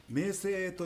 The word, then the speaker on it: 名声: 名称じゃなくて名声と読むみたい。